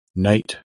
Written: Knight

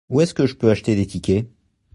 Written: tickets

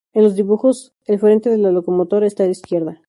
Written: locomotora

